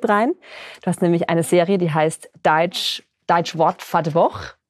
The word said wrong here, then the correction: Deutsche Wort für die Woche: Ist das richtig ausgesprochen? – Deutsche Wort für die Woche. – Deutsche Wort für die Woche, gar nicht so einfach.